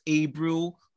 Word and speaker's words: Abreu: I think you pronounced your name wrong. Isn't it Abreu? – I don't want to say in the Spanish way, so yes.